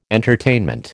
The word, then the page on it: entertainment: American English